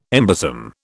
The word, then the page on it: embosom: British English